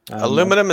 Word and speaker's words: aluminium: Aluminium, is that what you're saying? – Yeah. – Aluminium. Yes. Aluminium. No, I understand different countries, different pronunciations.